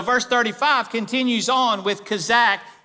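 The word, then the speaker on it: kazak (חזק): That word right there in the original Hebrew language is a word called kazak. It's spelled K-H-A-Z-A-Q. You may also find it spelled C-H-A-Z-A-Q. Kazak.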